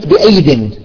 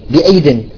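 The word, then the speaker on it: بأييد: لاحظوا العلماء أن في كلمات بالقرآن أحيانا في كلمة يزاد فيها الياء، مثلا كلمة تجد فيها ياء زائدة لا تلفظ، مثلا تقول مثلا كلمة بأيد، بأيد تراها ياءتين، ياء ثانية لا تلفظ، طيب هي شو الصفة؟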